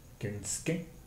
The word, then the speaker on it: Kensuke: Así pues, la persona, eh, la persona identificada como Kensuke, se pronuncia Ken, Kensuke. Kensuke. Kensuke Estamos en español.